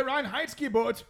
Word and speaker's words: Reinheitsgebot: Reinheitsgebot? – The Reinheitsgebot!